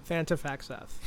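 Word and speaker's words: Thantifaxath: Thantafaxeth. – Thantafaxeth. So I just listened... – So the guy who's the front man of Ayahuasca is also the main brains behind Thantafaxeth.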